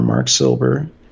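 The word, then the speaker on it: Marc Silber: M-A-R-C. S-I-L-B like boy E-R, Mark Silber.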